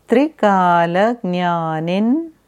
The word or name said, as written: Trikala Jnani